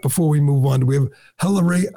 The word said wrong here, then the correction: Hilaria: I can't even say her name. Hilaria? – I think it's Hilaria Baldwin. – Hilaria Baldwin, the fake Spanish name for a fake Spanish person.